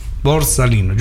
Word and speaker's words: borsalino: Borsalino, giusto? – Bravo, bravo, bene. Bravo. – Ho detto bene, complimenti.